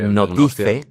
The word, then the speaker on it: notice: Notice. – Notice. – Notice, en inglés se pronuncia notice. – Notice. – Notice o notice. Las dos pronunciaciones, ¿vale?